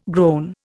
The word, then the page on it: groan: Indian